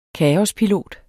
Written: kaospilot

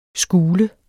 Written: skule

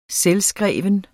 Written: selvskrevet

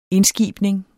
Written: indskibning

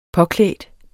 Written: påklædt

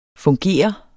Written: fungere

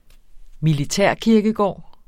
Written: militærkirkegård

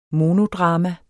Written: monodrama

